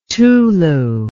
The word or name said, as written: Tulu